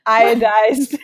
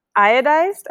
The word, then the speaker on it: iodized: What is it? Idosolized? – Iodized. – It's like one of those words where you never actually read it. You just know it's on the packet. – Right. – Wait, how do you say it? – Iodized, I'm pretty sure.